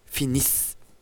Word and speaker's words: finis: Et le mot finis, là, on accentue la dernière syllabe. Et il y a ce son S à la fin.